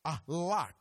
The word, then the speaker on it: halak: The word actually in the Hebrew means, it's a word that's pronounced halak, H-A-L-A-K, halak.